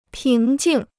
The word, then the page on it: 平静: píng jìng